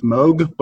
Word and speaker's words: Moog: And when I first got my first synth, it was Realistic MG1, which is like the Radio Shack version of I know it's pronounced Moog, but I've always called it Moog, so I will continue to call it a Moog. It's more fun to say it like that,